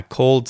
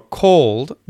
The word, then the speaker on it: called: So, for called, the vowel sound is the long or vowel sound, called, called, called. And for cold, it's the short oh vowel sound, like in hot, cold, cold, called, cold, called, cold, cold.